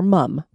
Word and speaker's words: Mum: And she's like, you got it right. It's just like the British name for mom over there, Mum.